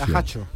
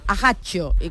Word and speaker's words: Ajaccio: ¿esto cómo se dice? En francés, con dos Cs. – Ajaccio. – Ajaccio, como italiano más o menos, ¿no?